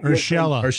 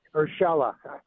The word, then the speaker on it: Urshela: Urshela. – Great. Urshela.